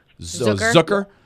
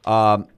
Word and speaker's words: Zucker: When you look at his name, it's spelled Zucker. They always had a little different pronunciation. It's more like Zook, like Hook. – Zucker. – Zucker.